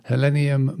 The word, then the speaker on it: Helenium: Yeah, just to pronounce it properly so we're clear with everyone, it's called Helenium morum beauty, common name Helen's flower.